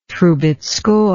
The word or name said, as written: trubetzkoy